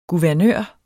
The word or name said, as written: guvernør